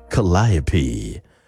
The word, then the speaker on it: Calliope: Calliope by Megan Maroney. You have to say it like that.